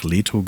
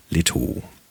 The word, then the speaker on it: Leto: Wird gerne mal im Deutschen einfach Leto genannt, aber wenn ich das richtig geblickt habe, wird die im Griechischen auf der letzten Silbe betont. Leto.